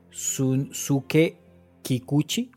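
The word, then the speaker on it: Shunsuke Kikuchi: Sunsuke Kikuchi.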